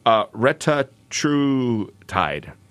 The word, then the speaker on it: retatrutide: It's Retatrutide.